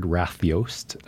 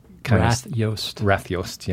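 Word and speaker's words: Rathljós: Tell me how you pronounce it. It's an Icelandic word. – It's a uniquely Icelandic word that I looked up specifically trying to find title for the exhibition, and this word Rathjost. Rathjost. – Yeah. – And that's probably even a bit of a bastardization of the true Icelandic pronunciation, but that's pretty close for us folks in English.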